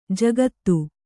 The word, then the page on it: ಜಕ್ಕಾತಿ: jakkāti